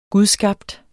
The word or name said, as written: gudskabt